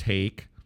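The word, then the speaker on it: Haake: Not only the Lester Holt beginning, but then when they did the story on McCarthy at 7:08, Garrett Hake, pronounced Hake, was reporting on, quote, a small but stubborn faction of right-wing members voting against party leader Kevin McCarthy.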